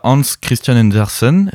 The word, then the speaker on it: Hans Christian Andersen: Quand on évoque euh, le Danemark, on pense tout de suite à Hans Christian Andersen.